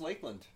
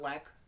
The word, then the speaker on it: Lakland: I think it's just Lakland. – Not Lac. – No. – Lakland. – Lakland. Because I think they have a little thing on the A. Lakland. Lakland. Like a lake. And that is a... It's a long A. – It's a long A. So, yeah. Long, sharp A.